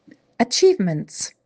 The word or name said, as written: Achievements